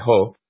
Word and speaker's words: JOH: Before the election, I would have said that it was a major blunder because it put Ho, as he's known, J-O-H, or his initials, and in Spanish you would pronounce that Ho,